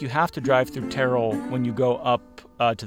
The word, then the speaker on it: Terrell: I believe Terrell is the correct pronunciation.